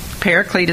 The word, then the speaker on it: Paraclete: Paraclete is the way we pronounce it in the Greek. It's parakletos. Everybody say that. Parakletos, but we say paraclete to make it easy for you.